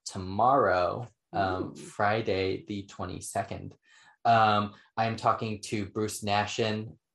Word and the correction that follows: Naxon: Naxon. I always mispronounce his last name, Bruce Naxon.